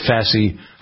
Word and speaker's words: fasciitis: We also talked about a new treatment for plantar fasciitis.